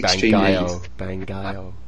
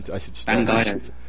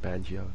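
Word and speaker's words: Bangai-O: It's actually Bangayo. I should Bangayo. – Yeah, although I have been calling it Banjo the whole time.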